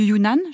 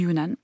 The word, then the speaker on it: Yunnan: À l'origine, elle contenait des Saint-Jacques ou des pétoncles séchés, des crevettes séchées, du jambon du Yunnan, je prononce bien ? – Oui, jambon du Yunnan.